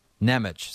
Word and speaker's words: Nemec: Sorry, Nemec. Did I say Natchez? Nemec. Simon Nemec. – I knew who you meant. There's an M. There's an E. There's an M. It sounds different than it's spelled.